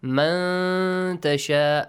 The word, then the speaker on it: من تشاء: Noon sakin, ikhfa. Hide the sound of the noon sakin here. Man tasha, a nasal sound also.